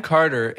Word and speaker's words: Carter: Phonetically, we don't naturally pronounce my name correctly. Mm-hmm. If we were to spell how everyone pronounces my name, it would be C-A-R-D, as in dog, mm-hmm. E-R. – You are. – Mm-hmm. Yeah, you are, yes. – Carter. – C-A-R-D-U-H-R. – Because to actually hear the T, mm-hmm.